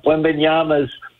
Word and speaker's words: Wembanyama: Last summer, the first person to go in the NBA draft, Victor Wembanyama. Did I pronounce it right? – Just Wembanyama.